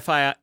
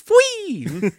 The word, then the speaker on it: Fwee: You're like, oh yeah, I'm gonna go to FI or FYI. It's like you're not saying it right. What do you mean I'm not saying it right? It's Fwee.